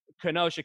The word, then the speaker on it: Kenosha: Hold on, they're breaking the law by having all that shit happen at the White House while all that's happening in Kenosha County.